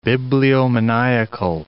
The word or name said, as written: bibliomaniacal